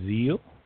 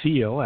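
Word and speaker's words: Ziel: Stage 2, I'm going to talk about the setting of the zeal, as is the seal, as it's correctly pronounced, with an S in the German, which is an ultimate destination point.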